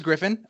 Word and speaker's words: Griffin: So, so my name is Griffin.